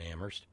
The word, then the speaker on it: Amherst: But there's still a very strong religious presence in New England, as somebody like, say, Kitty in Boston or Terry in Amherst, where only the H is silent, could tell us.